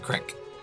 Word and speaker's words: creek: Like it was like a creek. – Any amount. Any amount, my dude. – It's actually pronounced crick.